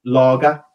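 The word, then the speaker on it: Lage: And, okay, yes, a hell of a lot of games managed less, but it's pronounced Lager.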